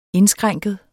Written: indskrænket

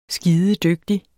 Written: skidedygtig

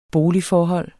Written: boligforhold

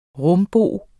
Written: rumbo

